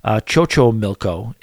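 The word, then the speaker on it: Xochimilco: Second night, we did Chocho Milco, as he provides me with the pronunciation, which I appreciate.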